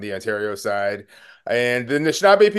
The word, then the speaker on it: Anishinaabe: Then the Anishinaabe people, it's part of my heritage.